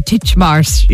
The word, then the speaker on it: Titchmarsh: Titchmarsh.